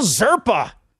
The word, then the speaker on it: Zerpa: Rookie Angel Zerpa. Rhymes with Sherpa.